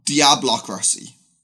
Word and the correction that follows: diabolocracy: Di- sorry, diabolocracy. Uh, that's a, how do you pronounce diabolocracy.